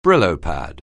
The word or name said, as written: Brillo pad®